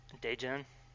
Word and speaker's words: Daejeon: Daejeon.